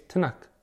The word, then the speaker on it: Tanakh: Okay, T-N-K. There are no vowels in the Hebrew language. So when you pronounce T-N-K, it's Tanakh.